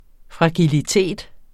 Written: fragilitet

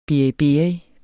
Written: beriberi

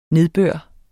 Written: nedbør

